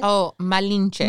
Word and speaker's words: Malinche: Malinche. I'm not sure I'm pronouncing it right. – Oh, Malinche. – Malinche. Yeah. Sorry.